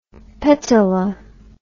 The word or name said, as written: petala